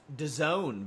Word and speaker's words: DAZN: As I get a notification from Netflix on my phone, It's pronounced DAZN, by the way, which is why apparently you have one vowel.